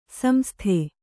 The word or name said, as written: ಸಂಸದ